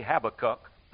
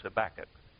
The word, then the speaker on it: Habakkuk: Habakkuk, or we, now if I say Habakkuk, that's the way we pronounce it overseas. So it's by Tennessee translation, it's Habakkuk, or Kentucky translation. I don't know which one.